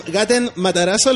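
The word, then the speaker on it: Gaten Matarazzo: Gaten Matarazo.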